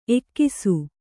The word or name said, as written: ಎಕ್ಕಿಸು